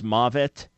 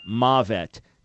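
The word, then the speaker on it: mavet (מוות): Yeah, M-A-H, ma, right? Then dash, V-E-T, mavet. Now, that's not a proper spelling, but that is how to pronounce it. M-A-H dash, V-E-T, mavet, death.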